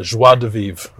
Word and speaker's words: joie de vivre: And he's I think such he, yeah, he's, nice, he's got a joie de vivre about yeah.